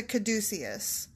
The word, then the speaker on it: Caduceus: Like, I grew up thinking it was Caduceus, and then as an adult, I was corrected several times, and no, it's Caduceus, but okay, excuse the hell out of me when I looked it up how to pronounce it, just to make sure I wasn't being a dumbo. – Yeah. – We're back to Caduceus.